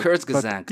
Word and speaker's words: Kurzgesagt: Do you know Kurzgesagt? I don't know how to pronounce it. – Kurzgesagt.